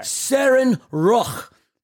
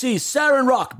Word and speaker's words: Seren Roc: Seren Roch. And it's spelled S-E-R-E-N-R-O-C. Seren Roch, baby.